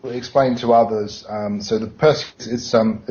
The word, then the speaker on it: Perseid: Because I'm not sure how to pronounce the name of the star thing that this guy's on about. – How soon will we be able to observe the Perseid shower? – Perseid. – Oh, Perseid, yeah. I was just too nervous about mispronouncing it, sorry.